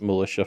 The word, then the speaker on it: Mylitia: It's like a cutesy militia for hire. – Sorry, I'm gonna retcon that. It's actually pronounced and spelled. I'll spell it out. It's Militia. – Oh, all lowercase. MYLITIA.